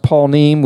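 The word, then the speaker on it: panim: It's pronounced paunim, which means nothing. I mean, we don't speak Hebrew, most of us, but it means the word face.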